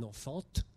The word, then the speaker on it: enfant: Vous voyez le T d'enfant qui, bien sûr, ne se prononce pas. Personne n'aurait l'idée de dire un enfant.